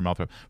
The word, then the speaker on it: Moulthrop: And Mr. Malthrop, first, please confirm that I'm pronouncing that correctly. – Yeah, you are.